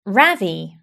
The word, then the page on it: ravi: British English